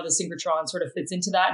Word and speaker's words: synchrotron: I think I'm saying that right. – The synchrotron, yes.